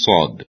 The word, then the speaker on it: صاد: حرف صاد را باید مانند سین اما پرحجم و درشت تلفظ کنیم. در علم تجوید به حالت پرحجمی صدا تفخیم گویند. بنابراین صاد سین تفخیم شده یعنی سین مفخم است.